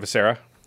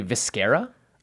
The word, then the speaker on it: Viscera: It's not Viscera. – It's actually Viscera, like mascara. – I thought it was like eviscerate. – No, it's Viscera.